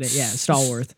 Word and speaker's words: Stallworth: I can see it is Stallworth with an L, but the way the characters pronounce it, I couldn't figure out if it was Stallworth or Starworth. – Oh, whether it was an L or an R, especially some of the heavier Southern accents pronounced it funny. But Stallworth.